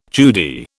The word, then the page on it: Judy: British English